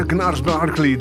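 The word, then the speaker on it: Gnarls Barkley: En hoe spreek je het uit, hè? Gnars Barkley, dacht ik. Inderdaad wel.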